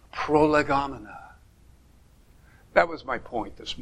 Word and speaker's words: prolegomena: And if you want to pronounce the Greek, it's prolegomena. But when you're a theologian making up big words, you say prolegomena.